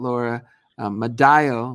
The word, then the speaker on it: Laura Madaio: If you want to check out Laura Madaio, am I saying it right? – Yes, you got it.